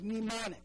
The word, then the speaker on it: mnemonic: We use the word mnemonic, right? Spelled with an mn, you only say the n.